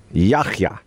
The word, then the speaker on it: Yahya: Yahya. – Yahya. – Yeah, it's not a hard K sound. It's like Yahya.